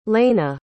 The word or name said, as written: leyna